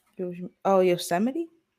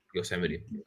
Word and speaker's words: Yosemite: I don't remember, but there were different Yo's Gemini, whatever you pronounce it. With Gunna and Nav. – Yosemite? – Yosemite. That's a better word to pronounce it.